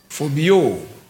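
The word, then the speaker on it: phobeo: The word translated afraid was spell something like P-H-O-B-E-O. P-H-O-B-E-O. What does it sound? Phobio.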